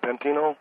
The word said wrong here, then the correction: Paterno: Joe Paterno. Joe Paterno. – I'm sorry. Joe Paterno, you know, that's what I'm saying.